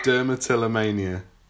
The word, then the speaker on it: Dermatillomania: So I'm going to try and say this right. Project Dermatillomania. – That's it.